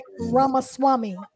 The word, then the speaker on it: Ramaswamy: Vivek Ramaswamy.